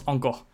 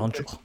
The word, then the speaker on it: Anchor: Ça se prononce encore. Encore. Ouais, mais c'est pas joli donc on va dire Anchor. – Désolé, c'est comme ça. C'est la règle.